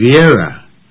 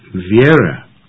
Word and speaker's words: Vera: V-E-R-A. – Oh, you mean Vera. The name is pronounced Vera, not Vera, as in Vera was here.